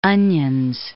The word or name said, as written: Onions